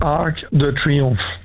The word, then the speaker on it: Arc de Triomphe: Arc de Triomphe.